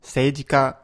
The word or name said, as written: seijika